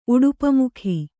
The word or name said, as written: ಉಡುಪಮುಖಿ